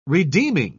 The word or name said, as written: redeeming